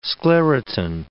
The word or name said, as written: sclerotin